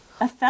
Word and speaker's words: Athelstan: Athelstan. – I'm going to spell it for you. A-T-H-E-L-S-T-A-N. Athelstan. Athelstan Braxton Hicks.